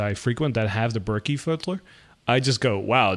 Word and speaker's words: Berkey: Berkey water filter. So it's spelled B-E-R-K-E-Y.